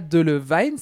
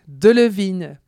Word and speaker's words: Delevingne: C'est pas de Levine, c'est pas de la vigne. On l'écoute elle-même nous dire comment ça se prononce. Hi, I'm Cara Delevingne and I'm... Voilà, c'est donc Delevingne.